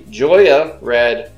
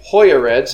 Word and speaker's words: Joya: So we are smoking, heard this pronounced two ways, the Joya Red, or probably more accurate, the Hoya Red, since we are from Nicaragua. – Ron Burgundy agrees. It's a soft J. It's like Yogi.